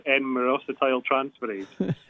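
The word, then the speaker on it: N-myristoyltransferase: So I believe you pronounce it N-myrosetyltransferase.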